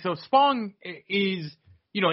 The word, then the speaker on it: Spong: How do you say his name? Spong? – Spong, yeah. – You sure it's not Sponge? – I'm positive, yes.